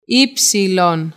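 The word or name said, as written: ύψιλον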